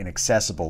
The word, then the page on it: inaccessible: American English